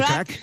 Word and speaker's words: craic: Con lo cual ahí podremos, decirte a ti y al resto de componentes de The Corrs, what's the craic? Creo que lo he dicho bien. – What's the crack? – What's the crack? – What's the crack? – Es como el how are you, pero en irlandés, ¿vale?